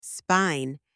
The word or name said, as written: spine